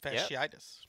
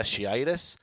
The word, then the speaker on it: fasciitis: He has a case of plantar fasciitis. Is that how you pronounce it? – Fasciitis. – Yep. Fasciitis. That means Brock Osweiler gets the start.